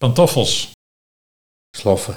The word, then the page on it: Sloffen: Nieuw lekkerlands